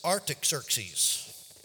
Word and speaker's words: Artaxerxes: The king was Artaxerxes, and that's exactly how they pronounced it.